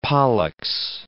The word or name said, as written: Pollux